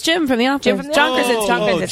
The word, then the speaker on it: Krasinski: Krasinski. – Krasinski.